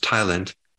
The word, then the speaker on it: ptyalin: There's this enzyme called ptyalin, which the P is silent, but it's pronounced ptyalin,